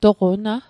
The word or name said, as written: toqo na